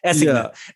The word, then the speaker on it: assignat: And if you study currency a little more deep in history, I look at like the French I think it's pronounced assignat, the assignat, as we would say it in American French. – don't even try. Just assignat.